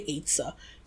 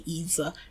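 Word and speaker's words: ezer: So, and the reason I say that a woman is an Etzah or Etzah, however you pronounce it, E-Z-E-R. I say Etzah. Some people say Etzah. I've heard several pronunciations.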